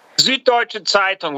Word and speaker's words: Süddeutsche Zeitung: Süddeutsche Zeitung, one of the most important independent broadsheets in Germany.